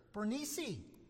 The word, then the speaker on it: Bernice: So now he stands on trial before Festus and his sister, Bernice. As correctly stated, I was reminded by my fellow in residence here. That's how you say it.